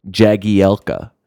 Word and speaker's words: Jagielka: Various shortenings of his name, or the regular pronunciation I think we've all been really used to is Jagielka. – That's right. – Yes, that sounds right.